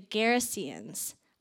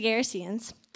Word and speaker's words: Gerasenes: Gerasenes. I did have to look up how to say that. That's how you say it. Country of the Gerasenes.